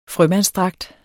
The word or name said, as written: frømandsdragt